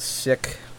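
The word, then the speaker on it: Scyk: Yep, the Sick Fighter, I think is how it's pronounced. It's S-C-Y-K.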